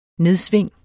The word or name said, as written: nedsving